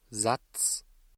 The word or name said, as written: Satz